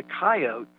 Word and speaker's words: coyote: So they shortened it to two syllables, to coyote. And they took that pronunciation back to the South and to the Midwest and to many of the rural places in the West where they ended up settling. And so we've got this kind of interesting bifurcation of two different ways to pronounce the word.